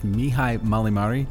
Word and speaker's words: Mihai Mălaimare: All right, so I'm here at a very fancy hotel in Century City with Mihai Mălaimare.